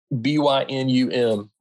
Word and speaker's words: Bynum: Jeff Bynum. What? Jeff Bynum. B Y N U M Bynum.